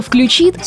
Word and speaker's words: включит: Как бы странно ни звучал правильный вариант, но именно «включит» с ударением на последний слог является литературной нормой русского языка.